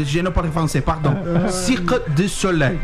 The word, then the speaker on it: Cirque du Soleil: Por favor, qual que é a pronúncia correta em francês, você que esteve lá durante muito tempo? Fala pertinho do microfone. – Cirque du Soleil.